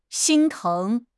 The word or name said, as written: 心疼